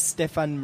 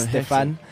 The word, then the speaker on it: Stephane: So, my name is Stephan Mare. It's spelled S-T-E-P-H-A-N-E. So it's almost like Stephanie, but it's Stefan.